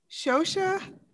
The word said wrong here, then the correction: Xhosa: or in. – Oh. That was so cute. okay. It's Xhosa.